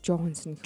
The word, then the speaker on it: Johansson: Johansson. – Johansson. – Johansson. Тэс хоёр дахь syllable дээр Hansen. Johansson.